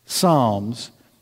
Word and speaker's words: Psalms: And then there's a book called Psalms. P.S. The P is silent. Psalms. P-S-A-L-M-S, the Psalms was their hymnal, but it was all in an ancient writing.